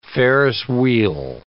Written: Ferris wheel

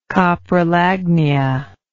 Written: coprolagnist

